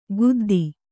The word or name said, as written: ಗುದ್ದಿ